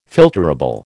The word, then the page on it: filterable: American English